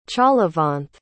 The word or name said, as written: chalavanth